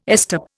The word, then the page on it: estop: Indian